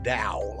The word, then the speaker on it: Tao: They pronounce it Tao, like a D, but it's spelled T-A-O-I-ism.